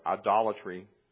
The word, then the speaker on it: idolatry: Most people who commit adultery today, or idolatry, pronouncing it correctly, most people who do that today worship the false god of secular humanism rather than idols of wood and stone.